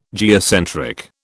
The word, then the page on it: geocentric: British English